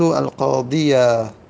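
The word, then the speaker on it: القاضيَ: أما در حالات النصب دیگه فتحه سبک هست، فتحه روی یا سبک هست، به خاطر همین عرب فتحه روی یا تلفظ میزنه، ظاهرش میکنه، میگیم رأیت القاضیه، رأیت القاضیه. پس القاضیه مفعول به منصوب و علامت نصب الفتحه، تو دیگه فتحه ظاهره، یا میگیم الفتحه تو ظاهره، تو بالاخره فتحه ظاهر هست.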